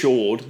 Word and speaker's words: Cord: So, I assume it's pronounced Cord. Could be pronounced Chord, but I assume it's Cord.